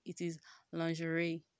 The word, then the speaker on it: lingerie: So, I'm just telling you, in case I've been pronouncing lingerie, it's not lingerie, it is lingerie.